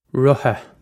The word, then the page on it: rotha: Irish